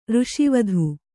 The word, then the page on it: ಋಷಿವಧು: řṣivadhu